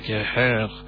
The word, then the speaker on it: Jair: Brazil's president is, hair, like as in with an H, but it's spelled J-A-I-R, like J-O-S-E, Jose, you say J-A-I-R, hair, Bolsonaro.